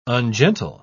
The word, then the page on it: ungentle: British English